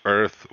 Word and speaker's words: Oearth: By the way, for those of you that are listening and not going to look at the links, it is Oearth with a silent O, right? – Yes.